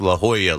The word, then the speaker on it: La Jolla: And then Dave Sims texts me and say, "Hey, it's pronounced La Jolla, like the Georgetown Hoyas."